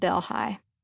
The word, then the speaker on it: Delhi: My grandmother had spent her childhood 40 miles away in a rural town called Delhi. Though Delhi was spelled like the capital of India, people in Louisiana pronounced it Del-Hi.